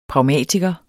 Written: pragmatiker

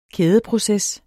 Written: kædeproces

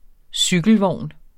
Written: cykelvogn

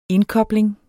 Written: indkobling

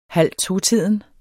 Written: halvtotiden

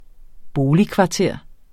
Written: BOLIGKVARTER

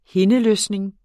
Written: hindeløsning

